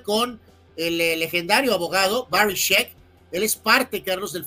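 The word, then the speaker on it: Scheck: Barry Sheck. Ah, eh, entendí otra cosa. Eh, bueno, por tu mente, es check Se escribe S-C-H-E-C-K. E check.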